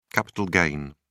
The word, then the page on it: capital gain: British English